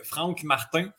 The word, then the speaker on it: Franck Martin: A battu Franck Martin. Prononcer à la française.